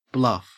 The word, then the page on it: bluff: Australian English